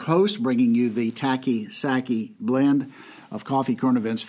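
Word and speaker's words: Psaki: So no, the P is silent. The P is silent. Sackey.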